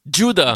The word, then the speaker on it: Giuda: Giuda. – Giuda, voilà que ça s'écrit Guida. – G-I-U-D-A.